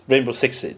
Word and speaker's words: Rainbow Six Siege: Vi ska ta upp lite Rainbow Six Siege. Är det Rainbow Six Siege? – Rainbow Six Siege. Stämmer mycket.